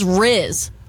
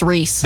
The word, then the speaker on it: Rhys: It's spelled R-H-Y-S. – R-H-Y-S? – Yes, ma'am. – Reese? – Is that Reese? – I had a kid in my class whose name was spelled like that, and he said it was pronounced Reese.